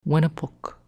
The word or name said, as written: Wenepuck